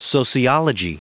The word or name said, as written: sociology